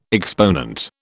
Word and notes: exponent: British English

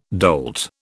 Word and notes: dolt: British English

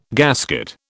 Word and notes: gasket: British English

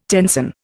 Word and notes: tenson: Indian

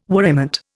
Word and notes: worriment: Indian

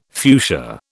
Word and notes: fuchsia: British English

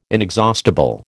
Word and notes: inexhaustible: American English

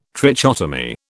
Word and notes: trichotomy: British English

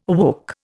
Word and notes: awoke: Indian

